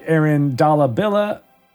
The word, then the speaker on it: Aaron Dalla Villa: He made sure to let us know it's just pronounced like Aaron Dalla Villa.